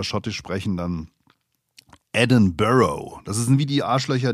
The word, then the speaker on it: Edinburgh: Ich hätte es jetzt fast wieder Edinburgh ausgesprochen, aber es heißt Edinburgh, wie ihr mich dann gleich verbessert habt auf Instagram, ihr Schluris, Edinburgh.